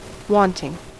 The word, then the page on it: wanting: British English